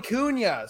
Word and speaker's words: Cunha: Yeah, it's Cunha. Yeah, so it's silent. I always tell people silent H out of Y. Cunha. It's Portuguese, man. – Oh, okay. Sorry. Portuguese. All right, Joey Cunha.